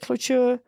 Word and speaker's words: cloture: cloture? – Yeah.